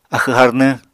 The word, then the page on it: axw harnwñ: apatani